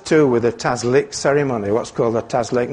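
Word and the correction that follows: Tashlich: Not sure if that's how you pronounce it. Somebody might correct me afterwards. What is it? – Tashlik.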